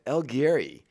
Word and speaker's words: El Guiri: El Guiri, I think, is the correct pronunciation of that.